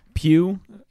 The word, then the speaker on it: Pugh: You got Florence Pugh, the GH is silent, who we know from Fighting With My Family.